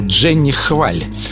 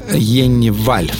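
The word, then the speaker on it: Jenny Hval: Правда, тогда я говорил Дженни Хваль. Но на самом деле Йенни Валь по-норвежски читается ее имя.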